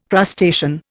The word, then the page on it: crustacean: Indian